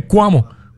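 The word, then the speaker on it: Coamo: Ah, lo dije bien, es Cuamo.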